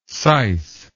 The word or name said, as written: syce